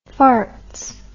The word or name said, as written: farts